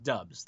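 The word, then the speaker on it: Doubs: I saw it pronounced Dubs. That's what the it's Dubs. Like.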